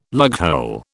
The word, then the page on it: lughole: British English